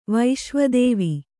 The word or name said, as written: ವಿಶ್ವೇದೇವ